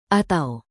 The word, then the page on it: atau: indonesian